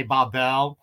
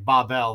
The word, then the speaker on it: Babel: The French say Babel. And in Hebrew, it's more like Babel. They actually stress the first part first. The Germans say it like Babel, you know, very similar to the way a lot of us would say over here.